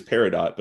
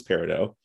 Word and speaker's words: peridot: Because in the show, her name is Peridot, but the actual stone is Peridot. – Yeah. The like silent T at the end.